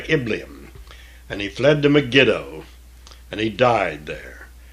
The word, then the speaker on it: Megiddo: And the servants, we said Megiddo, we often mix this up with the Battle of Armageddon, but it's really Megiddo. The emphasis here should be on the very last syllable, as it is in most Hebrew names and words, Megiddo.